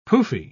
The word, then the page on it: pouffe: British English